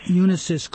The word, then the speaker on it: Unisys: Unisys Corporation.